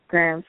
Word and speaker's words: Graham: It's just my name, Brittany Bella Graham. Graham spelled like the cracker, G-R-A-H-A-M.